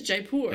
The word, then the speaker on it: Jaipur: No, I said Jaipur. – It is Jaipur.